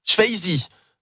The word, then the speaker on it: Swayze: J'ai mal prononcé. Patrick Swayze, Swayze, pas tout.